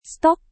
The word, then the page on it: stok: Indonesian